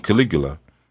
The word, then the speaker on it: Caligula: I said it right. Caligula.